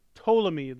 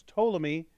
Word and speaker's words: Ptolemy: The third general is pronounced Ptolemy. The P is silent. It's Ptolemy.